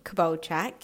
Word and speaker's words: Kubovcik: I'm also on Facebook, and that's by my name, so Courtney Kubovcik, K-U-B-O-V-C-I-K.